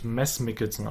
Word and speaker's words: Mads Mikkelsen: Übrigens, was ich jetzt herausgefunden habe letztens, kleiner Schock für mich, ich werde es wahrscheinlich trotzdem weiter sagen, Mads Mikkelsen wird eigentlich anders ausgesprochen. – Ach echt? – Ja, der wird, ist ja ein dänischer Name, ja. Wird eigentlich Mads Mikkelsen ausgesprochen. – Oh mein Gott. – So ein bisschen wie Mass Effect.